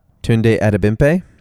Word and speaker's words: Tunde Adebimpe: Tunde Adebimpe.